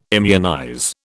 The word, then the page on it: immunize: British English